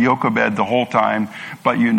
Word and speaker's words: Jochebed: Today, we're on a woman by the name of Jochebed, and I should say her name starts with a J. But in Dutch, they pronounce the J with a Y, and I simply cannot get that out of my head. So you can all think of J if you want.